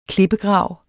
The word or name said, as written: klippegrav